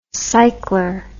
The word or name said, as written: cycler